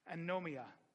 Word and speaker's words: anomia: The word lawless means is literally pronounced anomia.